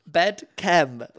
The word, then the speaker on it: BedChem: Pronouncing that like that was great. Bedchem. – Bedchem? – Bedchem. Like chemistry. What the fuck? – Is that what it's been all this time?